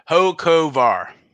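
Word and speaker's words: Hocevar: So that's between Haley Deegan, Chris Wright, Chandler Smith, Chase Purdy, and Carson Hockfar. – Hockfar. – Hey, I got to pronounce here. Hocovar.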